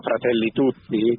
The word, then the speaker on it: Fratelli Tutti: Un poco el punto de partida es el mensaje del Papa Fratelli Tutti, presente en la encíclica Fratelli Tutti, como la fraternidad puede, a través de la vida consagrada, renovar al mundo.